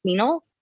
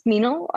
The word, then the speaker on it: Minal: actually, it's Minal. It's pronounced Minal. Just so you know, it's Minal.